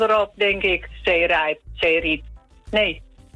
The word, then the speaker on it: Zeerijp: Met de klemtoon op Rijp. Want als je het in Groningen zegt, dan zeg je Zeerijp. – Zeerijp. – En dan is de klemtoon ook op. Op het riep in plaats van het rijp.